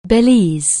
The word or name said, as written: Belize